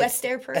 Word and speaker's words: Esther Perel: I heard you listening to a podcast from one of our idols, Mrs. Esther Perel, or how do you say it? Esther Perel. – Esther Perel.